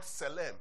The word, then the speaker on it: tselem: The word image is the Hebrew word tselem. I'll spell it for us. T-S-E-L-E-M. I'll spell it for us. The word their image is the word tselem. Tselem. Tselem. So, tselem, yeah, it's spelled T-S-E-L-E-M.